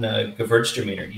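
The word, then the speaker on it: Gewürztraminer: And good call on Gewurztraminer.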